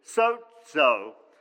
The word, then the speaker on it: sozo: It's sozo, and it's actually pronounced, or it's spelled S-O-Z-O, but it's almost, it's pronounced like S-O-T, sozo. It's pronounced sozo.